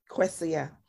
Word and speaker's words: Kwesia: And how do you pronounce it? – Kwesia.